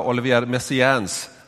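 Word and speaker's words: Olivier Messiaen: Jag tror att det mer ska vara Olivier Messiaens, att det ligger lite närmare sanningen än det som jag sa.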